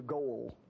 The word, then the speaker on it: goel: And in the normal sentence structure that we see in the Bible, that word is spelled G-O-E-L, goel.